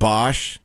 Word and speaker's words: Bosch: Like, Amazon Prime has, like, Bosch, based on the Harry Bosch books.